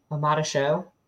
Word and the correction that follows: Aloisi Omotosu: Aloisi Omotosu.